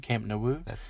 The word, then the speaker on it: Camp Nou: Camp Nou.